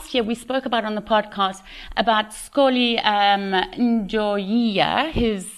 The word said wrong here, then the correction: Xoliswa: you're, you Can you pronounce Xoliswa. Xoliswa.